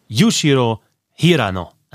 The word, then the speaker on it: Yushiro Hirano: Aber er heißt natürlich Yushiro Hirano. Ah, japanische Namen werden vorne betont.